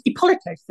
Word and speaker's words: Ippolito: Ippolito, though, is the Italian pronunciation, he's the second son of the Duke of Ferrara, Duke Alfonso, and his mother was the famous Lucretia Borgia.